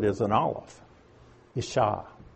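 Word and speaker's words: ish-ha (אשה): Aleph, it's a glottal stop. Again, it's very hard to pronounce. It's just ish-ha, because the I sound comes from that little dot underneath it. So it's ish-ha. But it's an aleph.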